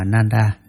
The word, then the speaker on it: Nanda: Ngài Nanda, Ấn Độ đọc là Nanda, chữ Hán là Khánh Hỷ, là em ruột của Phật.